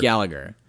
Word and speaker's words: Gallagher: Gallagher. – You don't pronounce the second G.